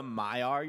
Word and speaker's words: Maiar: Did you say the mayor? – Yeah, basically the mayor. – Manway? – Manway. The most powerful of the... – No, I gotta correct a pronunciation. That's the Maiar you're talking about.